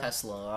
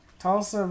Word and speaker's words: Tulsa: And Tulsa, I don't know how to say it, Tulsa, T-U-L-S-A. Tulsa. – Tulsa? Oh, I almost said Tesla.